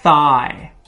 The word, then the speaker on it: thigh: Thigh. Thigh. Okay, that TH is difficult. The GH is silent. So it sounds like the word I with TH in front of it.